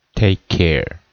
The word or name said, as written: take care